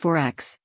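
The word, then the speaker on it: XXXX: 4X, pronounced 4X,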